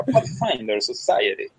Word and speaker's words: Pathfinder Society: Pero lo que sí pasa con tercera edición es que existe esto del juego organizado, las RPGA, Adventures League y la Pathfinder Society. Lo pronuncié muy feo, muy feo, así que lo voy a pronunciar de vuelta. La Pathfinder Society. Gracias. Sí, ese es el inglés moronense.